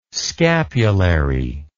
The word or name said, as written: scapulary